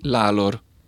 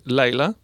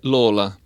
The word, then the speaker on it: Lalor: Invece ci sono due pronunce completamente diverse. C'è Laila. Laila è Lola.